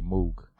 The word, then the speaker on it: Moog: So next beat is Self Serve, who will have May 13th, Face an Evil Doer, versus, Self Serve with Early, how do you pronounce that word? Is Moog. – It Moog? Moog? – Yeah, I say Moog.